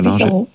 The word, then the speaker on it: Visão: Vous avez choisi l'hebdomadaire portugais Visão, que je prononce très mal. – Visão. Hein, Visão, oui, parfait.